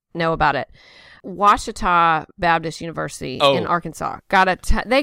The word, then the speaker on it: Ouachita: So, which is how I even said it right, Ouachita.